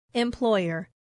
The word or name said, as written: employer